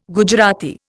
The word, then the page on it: Gujarati: Indian